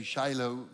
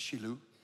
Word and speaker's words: Shiloh: We say Shiloh, but it's Shiloh.